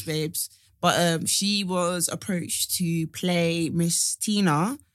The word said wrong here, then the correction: Tiana: Princess Tina, sorry. I hope I'm saying that right. From Disney. – Oh, yeah. Tiana. – Tiana.